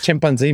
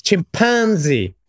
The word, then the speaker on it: chimpanzee: Not just that, it's not chimpanzee, Matt, it's chimpanzee.